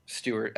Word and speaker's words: Stewart: And my name is spelled Stewart, S-T-E-W-A-R-T, not S-T-U-A-R-T.